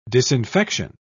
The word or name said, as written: disinfection